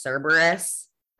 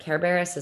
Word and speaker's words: Cerberus: Also learned that, because my whole life I've pronounced that word as Cerberus, like when I've read anything about like Greek mythology. But Kerberos is like the original Greek pronunciation, I guess.